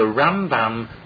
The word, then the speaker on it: Rambam: The Ramban, the accent is always on the second syllable. In the Rambam, the accent is on the first syllable.